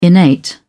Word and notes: innate: British English

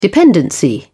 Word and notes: dependency: British English

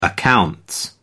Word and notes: accounts: American English

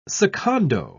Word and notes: secondo: British English

